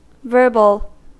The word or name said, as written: verbal